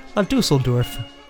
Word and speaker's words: La Düsseldorf: I can never pronounce it correctly. La Düsseldorf. Right? – Right. – The band's called La Düsseldorf.